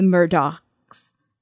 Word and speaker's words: Murdaugh: So, this is our second episode about this family, the Murdochs. – So, the GH is just pronounced like a K, correct? – Or it's just trails off. Murdoch.